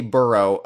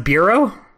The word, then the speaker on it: borough: Is it pronounced bureau? – No, it's... – I don't know. – No, it's not pronounced. Bureau.